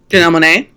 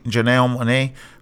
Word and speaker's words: Janelle Monáe: Is that how you pronounce her name? – Janelle Monáe. – Janelle Monáe, who I'm very surprised with.